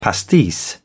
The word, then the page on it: pastis: British English